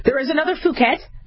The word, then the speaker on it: Phuket: and then there was a B Phuket. It's not pronounced Phuket.